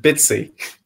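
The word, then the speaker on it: Bitci: I still have no idea how to pronounce it, but is it Bitsy? – Yes. – I still don't know how to pronounce it, to be fair. – I was going to say, I'd go for Bitsy.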